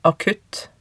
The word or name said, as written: akutt